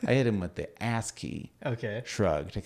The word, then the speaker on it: ASCII: Or ASCII. How's that pronounced? – Yeah, ASCII? – ASCII. – That's ASCII? Fine.